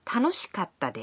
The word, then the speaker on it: 楽しかった: In this way, it's fun, 楽しいです, for example, becomes 楽しかったです, meaning it was fun. – Listen and repeat. Now, 買った is pronounced with a brief pause in between か and た.